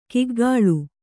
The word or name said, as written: ಕಿಗ್ಗಾಳು